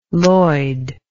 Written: loid

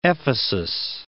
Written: Ephesus

